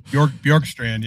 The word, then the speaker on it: Bjorkstrand: And I'm probably going to pronounce this wrong, but they lost Oliver Bjorkstrand. – Bjorkstrand, yeah. – Yeah, there you go.